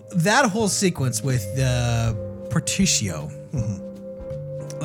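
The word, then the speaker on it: Partitio: I think the way you pronounce the merchant's name is Particio. There's an R in there, I want to say.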